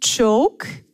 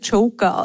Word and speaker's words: Choke: Also es heißt, okay, es heißt eigentlich Choke, C-H-O-K-E, oft fälschlicherweise Joker ausgesprochen.